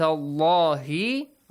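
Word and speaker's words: الله: وَذْكُرُوا نِعْمَةَ اللَّهِ عَلَيْكُمْ. There is no special rule, just the letter lam in the word Allah is pronounced heavily.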